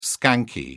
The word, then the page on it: skanky: British English